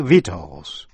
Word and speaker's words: victuals: Và shus, T-I-O-U-S, như trong chữ conscientious, là tận tâm. Và phần cuối là C câm, tức là không phát âm, như trong chữ vitals. Vitals, V-I-C-T-U-A-L-S, là thực phẩm.